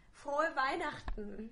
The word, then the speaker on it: Frohe Weihnachten: Frohe Weihnachten. – Slower. – Say it. – One more time. – Frohe Weihnachten. – Frohe Weihnachten. – No, it's not. Weihnachten. – Frohe Weihnachten.